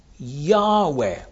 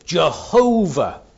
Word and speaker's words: Yahweh: What you've got there is a name, Yahweh or Jehovah. It can be pronounced either way.